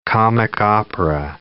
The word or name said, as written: comic-opera